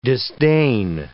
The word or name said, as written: disdain